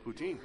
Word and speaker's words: poutine: And met a lot of people, and it was a great time, and had my first – poutine, and now I love poutine. – And yes, you pronounced that correctly.